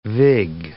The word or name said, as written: vig